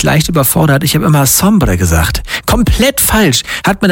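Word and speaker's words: Sombr: Es ist natürlich Sombre. So wird er ausgesprochen, aber am Ende steht ein R und kein A.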